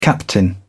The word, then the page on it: captain: British English